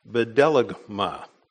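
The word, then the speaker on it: bedelagma (βδέλυγμα): And the word that's translated abomination here, the original Greek word, is pronounced bedelagma. And I did a little reading on it this week, and I found a reference to the fact that bedelagma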